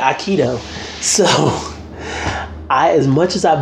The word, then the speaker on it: Aikido: People can pronounce it a little different. – Spell it. – A-I-K-I-D-O. – Okay, Aikido. I was like, Ikea? Aikido, thank you.